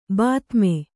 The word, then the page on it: ಬಾತ್ಮಿ: Kannada